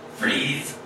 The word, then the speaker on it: Frise: Pretty sure it's pronounced Freeze. – I am excited to meet me. I wager I am spectacular. – Much like his robotic double, this Bichon Frise is wrong.